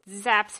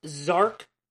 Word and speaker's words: Zark: And just zaps him with the eye beams, and she's like, "Thanks, Dad." – It's pronounced Zark, not Zap. – Zark? – Yeah. That is the correct onomatopoeia.